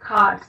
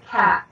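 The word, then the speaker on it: Khat: I pronounce it cot. The British tend to pronounce it cat.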